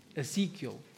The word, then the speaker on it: Ezekiel: How do you pronounce that? Ezekiel. Thank you.